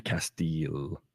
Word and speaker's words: Castile: Yeah, Castile.